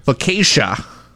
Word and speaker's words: focaccia: And focaccia. More bread. – Focaccia. – Focaccia? – Focaccia. – Still bread. – Focaccia.